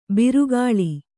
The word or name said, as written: ಬಿರುಗಾಳಿ